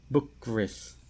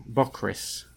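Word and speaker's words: Bockris: Well, I read a book called Patti Smith, and it's by a fella called Victor Bokris, I think it's pronounced. Bokris.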